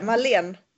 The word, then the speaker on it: Malene Birger: Men Marlene Birger, eller hur säger man? – Ja, utan R-et. Det är inget R där. Marlene.